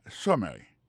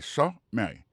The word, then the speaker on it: summary: pour question de prononciation accent tombé sous premier syllabe là summary ça c'est summary ça veut dire résumé résumé et summary of your education and work.